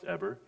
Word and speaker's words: Eber: I used to say Eber, but it's pronounced Eber.